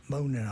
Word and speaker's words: Moanin': The album was called Moanin. Moanin, I have to keep saying how to spell it. M-O-A-N-I-N apostrophe. It's like moaning with a G off.